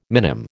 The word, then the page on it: minim: American English